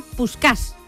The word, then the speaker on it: Puskás: No es de extrañar que al final se le terminase apodando Puskás, que en realidad al principio era Puskás, con el acento en la A, ¿no?, en húngaro. Significa escopeta en su idioma, en húngaro, Puskás, ¿no?